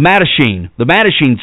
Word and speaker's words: Mattachine: For the past several years, the Machete Society of Washington, I guess that's how you say it, the Mattachine Society of Washington, a revised version of a group originally founded in 1961, has been seeking to document evidence of the U.S.